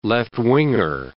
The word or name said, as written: left-winger